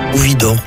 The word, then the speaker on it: Widor: Alors, le troisième morceau de musique retenu est la toccata de Charles-Marie Widor. Je suppose que je prononce bien. – Widor. Les deux sont acceptables.